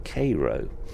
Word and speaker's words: Cairo: As the night progresses, we will pass through Cairo, which is pronounced Cairo, a river port at the junction of the Ohio and Mississippi rivers.